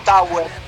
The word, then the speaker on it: Tawer: Lo buscan como Tower. Tower se escribe T-A-W-E-R. Tower, como torpe en inglés con falta de ortografía, me con O con A, o como huerta al revés. Tower, huerta. Entonces ponen Tower en YouTube y ahí aparece.